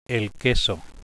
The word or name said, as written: queso